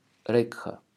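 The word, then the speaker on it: рекха (रेखा): Хая буква не читается, поэтому она звучит как река. Так, таким образом, линия, которая огибает большой палец, будет называться шукра рекха.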